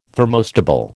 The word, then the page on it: thermostable: American English